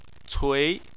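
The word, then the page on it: cui: tone 2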